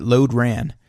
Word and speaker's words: Lodran: As it's spelled exactly how I'm saying it. Lodran.